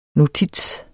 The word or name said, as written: notits